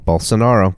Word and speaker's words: Bolsonaro: Bolsonaro. B-O-L-S-O-N-A-R-O.